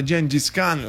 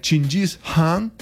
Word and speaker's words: Chinggis Khan: Però quello che resta di concreto, quello che veramente è vero, è che l'impero creato da Genghis Khan, la cui pronuncia corretta in realtà è Chinggis Khan con l'H aspirata,